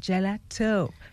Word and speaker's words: GelaToh: I'm saying it with that tone, GelaToh. – Because you're saying it right. – I'm glad. Because the toe at the end has a T-O-H, right? – Yes.